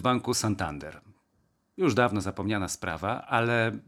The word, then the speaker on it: Santander: Mój szef, który jest iberystą, powiedział mi, że powinno mówić się Santander z akcentem na ostatnią sylabę. Tak jakby po francusku człowiek mówił jak Pascal Brodnicki, mój przyjaciel, który ma tę uroczą manierę mówiąc w języku polskim, że akcentuje ostatnie sylaby. No więc to jest Santander.